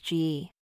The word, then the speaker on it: gi: The answer is G.I.G. – The proper oriental name for the white suits often used in karate and judo is G.I., pronounced G.E.